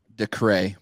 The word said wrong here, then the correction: Dacre: Decray? Whatever. I can't fucking pronounce shit. – It's Dacre Montgomery.